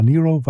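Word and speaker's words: Nyro: Ich habe die auch jahrelang Nairo genannt oder sowas, aber Nero wird sie tatsächlich ausgesprochen.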